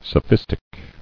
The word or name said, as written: Sophistical